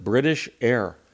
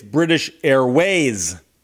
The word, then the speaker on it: British Airways: How should I pronounce it? British Air. And the person wrote, it's British Airways. Airways. It's two syllables. You kept leaving off the second syllable of the second word.